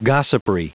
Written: gossipry

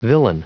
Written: villein

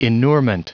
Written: inurement

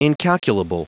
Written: incalculable

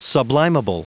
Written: sublimable

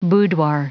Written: boudoir

